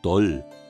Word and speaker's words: Tal: In der nordbayerischen Mundart der Oberpfalz, aber auch in den fränkischen Mundarten, wird aus dem L in Tal kein I gemacht. Während Tal im Süden Deu ausgesprochen wird, heißt es im Norden Doll.